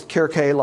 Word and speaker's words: Kirke: Words like chrome, C-H-R-O-M-E, still have that hard K sound with a C-H, but then there's the soft C-H, and so instead of pronouncing it kirke when it came over here, we just pronounced it with a soft C-H and it became church.